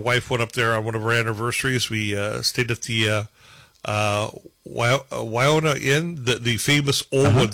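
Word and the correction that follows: Ahwahnee: Awani? Is it the Awani? – Awani. I couldn't pronounce it correctly there. Awani Inn.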